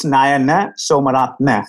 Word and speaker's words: Nayana Samaratna: It's Nayana Samaratna. So the A's at the end are kind of short.